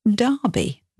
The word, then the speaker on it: Derby: So place names that have letter E that's pronounced like an A. So an example of this would be Derby, the city, spelt D-E-R-B-Y, and Derbyshire, the county. Think about Derby County Football Club and cricket.